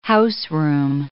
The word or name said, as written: houseroom